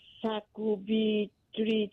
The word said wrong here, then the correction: sacubitril: tức là SACUBITRIL. Cái thuốc đó là thuốc gì ạ? – Cô đọc lại cho con ấy. SA gì nữa cô? – SACUBITRIL.